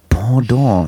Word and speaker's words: Pendant: Ich denke, was steht da Hamburger Pedant zu Mixi TV? Ich denke, okay, er wird schon wissen. Ja. Pendant.